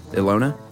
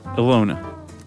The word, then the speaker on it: Ilona: I was actually shown them by my friend, how would you pronounce I-L-O-N-A? Ilona? – Ilona.